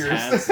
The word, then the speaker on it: Uyghur: That's like, when I saw it spelled out, I was like, how the fuck do you pronounce that? And I listened to the video, or I watched the video, and it was like Uyghur.